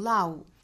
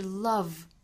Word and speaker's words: love: 我听到过有很多同学仍然把爱这个词就是 L-O-V-E 这个词读成 love, 应该是 love。我们看到字母 V, 一般对应到发音之中呢就是上齿摩擦下嘴唇发 V, love。